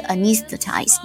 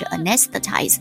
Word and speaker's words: anesthetize: 所以我今天看这个 anesthetize 的时候，我说然后我在听那个例句啊，他读的是 anesthetize，然后我就去查了一下我的字典呢，发现就是两种读音，一个是 anesthetize，一个是 anesthetize，讲很多对不对？